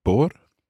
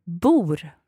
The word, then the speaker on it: bor: Bor. – Bor. So it's like bor. It's almost like B-O-O-R.